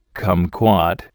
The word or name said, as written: kumquat